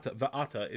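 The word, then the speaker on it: Va'ata (ואתה): Normally, Va'ata is spelled with a Vav Aleph Tuf Hey, Va'ata. That's the Cree, how we pronounce it.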